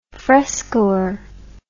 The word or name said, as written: frescoer